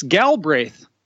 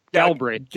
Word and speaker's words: Galbraith: How do you say it? – Yeah, you know what? Galbraith. G-A-L-B. Yeah, Galbraith. – Jason Galbraith!